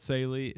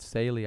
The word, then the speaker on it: Saleh: Robert Saleh. I've never heard it pronounced like that. I'm not going to lie. I always pronounce it Robert Saleh, but, you know, whatever works.